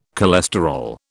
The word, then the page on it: cholesterol: British English